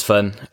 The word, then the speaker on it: Fenn: Not that I don't like Finlay, it's just I play touch and it tends to be easier to say Fenn than Finlay, one less syllable, you know what I mean? Yeah, my name is Fenn.